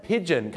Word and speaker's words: pigeon: ออกเสียงว่า pigeon ไม่ใช่ผีเจี้ยนนะฮะบางคนออกเสียงผีเจี้ยนไม่ต้องเจี้ยนนะฮะ pigeon